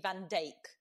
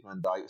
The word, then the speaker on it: Van Dijk: We've discovered that Virgil his surname is actually Van Dijk. – Oh, is it? Okay, yes. I would have pronounced it like Dick Van Dyke, so I would have got that wrong.